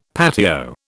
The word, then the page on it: patio: British English